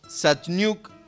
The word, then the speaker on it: Szachniuk: con Nadia Satniuk. A ver si lo dije bien así. ¿Cómo te va Nadia? Buenas noches. – Hola, ¿qué tal? Lo dijiste perfecto, mejor que yo.